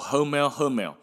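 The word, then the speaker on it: Hummel: 哦，用一句话笑他叫做 “Hermel Hermel”，那个发音哦，有一点像是台语的“ 赫北赫北” 啦。哦，阿是讲红妹，就是好了没的赫北，跟被骂被人家骂的红妹。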